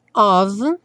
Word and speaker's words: of: Aqui, revisando of, no caso com som de V, esse F com som de V, e o love, como é, não é pronunciado, já vai ligar direto no O, né?